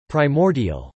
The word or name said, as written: primordial